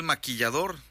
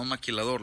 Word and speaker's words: maquillador: Así es, Día Internacional del Maquillador. Sí, maquillador, no maquilador, lo dije bien.